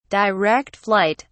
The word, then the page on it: direct flight: British English